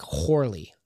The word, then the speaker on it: Corley: And in the Gifford guide, it says that it's pronounced, the way it's translated is with an H sound. So it'd be pronounced like Horley, like, and one could even think of that as W-H-O-R-E-L-Y,